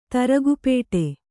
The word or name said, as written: ತರಗುಪೇಟೆ